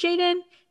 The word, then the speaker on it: JXDN: But yeah, no, this is spelled JXDN. And I actually like looked it up. I was like, is it? I mean, I'm assuming it's pronounced Jaden.